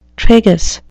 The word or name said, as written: tragus